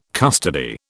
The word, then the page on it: custody: British English